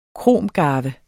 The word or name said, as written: kromgarve